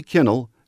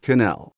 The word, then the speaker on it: Kinnell: to start pronouncing his name right. Tyree Kinnell, not Kinnell.